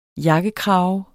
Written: jakkekrave

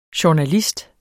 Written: JOURNALISTERNE

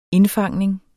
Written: indfangning

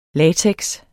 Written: latex

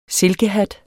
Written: silkehat

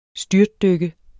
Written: STYRTDYKKE

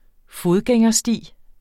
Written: fodgængersti

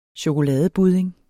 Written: chokoladebudding

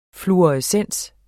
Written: fluorescens

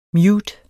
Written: mute

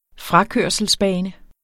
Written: frakørselsbane

